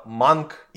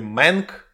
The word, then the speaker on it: Mank: Новый год наступил 4 декабря, потому что дядюшка Дэвид Финчер принес нам под елочку свой новый, одиннадцатый фильм, который, естественно, называется Манг или Мэнг, называйте его, с тем акцентом и с тем произношением буквы «А», как хотите.